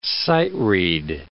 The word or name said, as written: sight-read